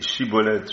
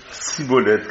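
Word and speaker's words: Schibboleth: On disait alors, dis Chibolette, je te prie. Et ils disaient Sibolette, car ils ne pouvaient pas bien prononcer.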